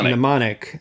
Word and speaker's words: Mnemonic: Mnemonic.